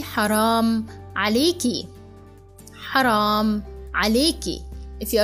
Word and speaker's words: حرام: And there is ha at the beginning, okay? حرام, حرام. And remember, Arab people, they pronounce ra like this way. That strong ra. Actually, strong any letter.